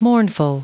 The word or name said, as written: mournful